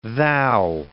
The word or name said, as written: thou